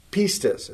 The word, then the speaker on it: pistis: The Greek word for faith is pistis. It's spelled P-I-S-T-I-S.